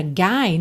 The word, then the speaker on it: guy: Atenção então já à pronúncia dessa palavra guy, né, que é o cara, que se escreve G-U-Y, tá? E é guy.